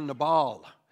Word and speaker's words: Nabal: You could pronounce his name Nabal or Nabal.